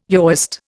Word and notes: Yahwist: Indian

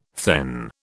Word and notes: thegn: British English

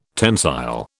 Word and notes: tensile: British English

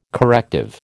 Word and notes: corrective: American English

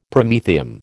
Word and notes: Promethium: American English

